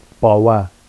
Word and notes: bɑwa: Vafsi

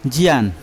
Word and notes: jian: Yawa

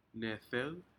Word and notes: nedec: Aneityum

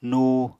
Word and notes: nuu: Apatani

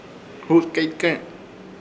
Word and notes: Hutkeykẽk: Ishir; Chamacoco

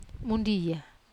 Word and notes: mundia: Wamesa

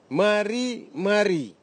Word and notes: marimari: Nafe; Tanna

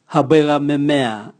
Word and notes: habwega memea: Raga

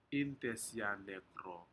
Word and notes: intesianekro: Aneityum

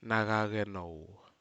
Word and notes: nacalcenou: Aneityum

